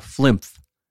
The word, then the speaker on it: Flimph: Flimph. And I said that right.